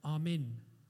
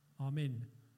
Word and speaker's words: Amen: And when I was talking to Radhika about this earlier, in India, people pronounce it slightly differently, say Amin. Amin. And Radhika said, it's as if they're saying, I'm in.